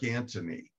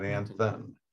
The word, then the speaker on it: Anthon: And the reason why we know that is because I believe it was his nephew or someone actually explains the pronunciation of their last name. Huh. And it's Anton, not Anthon. So anyway, oh, there you go.